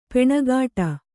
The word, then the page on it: ಪೆಣಗಾಟ: kannada; peṇagāṭa